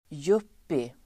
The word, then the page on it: yuppie: [jup:i]